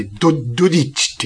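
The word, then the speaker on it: dodici: 12 って書いて、ドディッチって読むらしいよ、イタリア語で。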